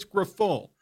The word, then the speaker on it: Grifol: Is it Pedro Grifol? I don't even know how to pronounce his name. Coach me up on this. – It's Grifol. Pedro Grifol.